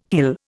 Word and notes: hill: Indian

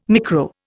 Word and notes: micro: Indian

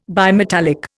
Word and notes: bimetallic: Indian

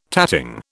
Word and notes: tatting: British English

